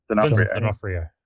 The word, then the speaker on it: D'Onofrio: How do you pronounce his last name? D'Onofrio. – D'Onofrio.